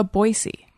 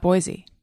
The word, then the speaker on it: Boise: Apparently it's also Boise, not Boise. – Really? – They will yell at you, I guess. – Boise instead Boise. Of Boise.